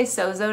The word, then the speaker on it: sozo: In the New Testament, the word is sozo, S-O-Z-O. I say sozo to be lazy kind of, because that's phonetically the way I look at it, but it's sozo, like there's a D in it.